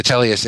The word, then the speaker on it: Teleos: Am I saying that correctly, Telios? – Yes, you are. – Great, the Telios Institute.